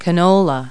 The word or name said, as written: canola